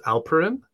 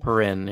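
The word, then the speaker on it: Alperen: He, I believe his first name is pronounced Alperin. I'm assuming it's Alperin, right? Alperin Sengun. – Alperin.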